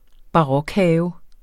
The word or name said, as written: barokhave